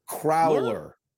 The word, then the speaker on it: crowler: Number two now, I need you to pronounce this again for me because I think you're saying Crowler. Crowler. Like it's growler, but with a C instead of a G.